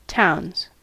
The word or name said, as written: towns